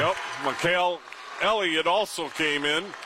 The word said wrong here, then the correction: Mikhail: Michael Elliott is how you pronounce it, I was told.